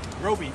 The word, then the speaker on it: Roby: Roby Mitchell. – Roby? – Yep. – How do you spell it? – R-O-B-Y.